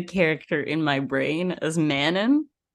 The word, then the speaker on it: Manon: It's actually supposed to be pronounced Manon.